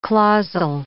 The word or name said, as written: clausal